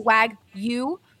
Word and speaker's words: Wagyu: Hassel Cattle Company is the absolute best source for farm to table Wagyu, we found out today that it's Wagyu, not Wagyu.